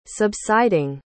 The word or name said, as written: subsiding